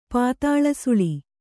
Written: ಪಾತಾಳಸುಳಿ

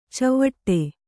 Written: ಚಿವುಟು

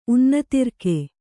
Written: ಉನ್ನತಿರ್ಕೆ

